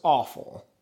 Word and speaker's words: offal: Organ meat is referred to as awful, which is not awful as in this is awful. Like O-F-F-A-L. – O-F-F-A-L is the word for organ meat. It's pronounced awful.